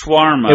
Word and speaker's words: shawarma: We say it wrong on the show. – No, it's shawarma.